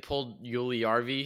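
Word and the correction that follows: Puljujärvi: Is that how you say his name? – Jesse Puljujarvi.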